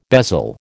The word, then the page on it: bezel: American English